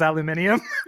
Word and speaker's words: aluminium: In the like cartoon version of this, it pans across all those metals and there is aluminum, and right next to it is aluminium. They have two. – Oh, one for the British pronunciation and one for the American.